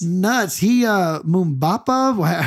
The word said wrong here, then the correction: Divin Mubama: How do you say his name? So it's it's Divin uh, Mubama. – Divin Mubama.